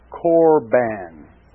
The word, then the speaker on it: Corban: The word is pronounced in the Greek, Korban. C-O-R-B-A-N.